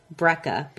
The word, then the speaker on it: Breca: This is a lovely bottle of Breca, B-R-E-C-A.